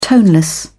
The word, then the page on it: toneless: British English